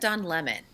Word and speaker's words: Don Lemon: Is it Don Lemon or Don Lemon? Because I've heard people go both ways. Oh yeah, that. oh yeah. I've only heard Don Lemon. – Don Lemon. I suppose because it rhymes.